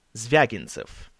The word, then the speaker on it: Zvyagintsev: The director of The Return, Exile, and Yelena is Andrei Zvyagintsev.